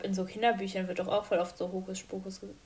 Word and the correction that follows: Hokus Pokus: Jetzt habe ich es selber falsch gesagt. Hokus Pokus gesagt.